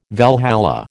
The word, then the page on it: Valhalla: American English